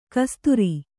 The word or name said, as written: ಕಸದಾರ